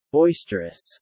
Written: boisterous

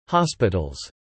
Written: hospitals

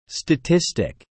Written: statistic

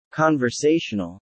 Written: conversational

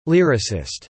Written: Lyricist

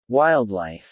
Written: wildlife

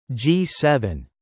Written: G7

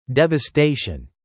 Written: devastation

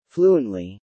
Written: fluently

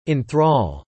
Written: in thrall

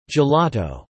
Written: gelato

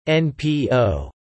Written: NPO

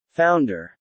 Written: founder

